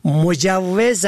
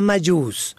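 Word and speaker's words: مجوّز: در جمله ای گفت آغاز کار بعد از اخذ مجوز کاری ممکن است، در حالی که این کلمه مجوز است نه مجوز.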